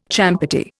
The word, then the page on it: champerty: Indian